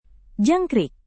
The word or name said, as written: jangkrik